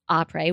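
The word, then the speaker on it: Après: That's D-R-I-N-K, drink, A-P-R-E-S, Opry with a silent S, .com.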